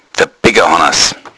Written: beckerhannas

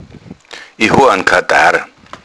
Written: kartharr

